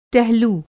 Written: टहलू

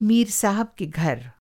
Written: साहब

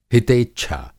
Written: हितेच्छा

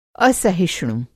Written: असहिष्णु

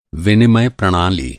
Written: विनिमय प्रणाली

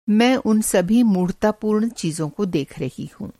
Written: मूढ़तापूर्ण

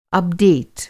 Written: अपडेट

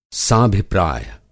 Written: साभिप्राय

